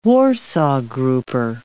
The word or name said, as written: warsaw grouper